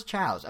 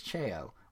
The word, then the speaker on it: Chao: Apparently, it's pronounced. I thought it was chows. Apparently, it's chao, which is like someone just doesn't know how to read the word chow, I feel like.